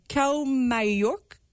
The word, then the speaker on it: Kaumayuk: J'espère que je l'ai bien prononcé, mais tu peux me corriger si je l'ai mal dit. Kaumayuk, qui veut dire bien éclairé, si je ne me trompe pas. – Oui, c'est ça.